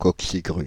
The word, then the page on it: coquecigrue: France (Île-de-France); /kɔk.si.ɡʁy/